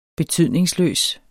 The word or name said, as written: betydningsløs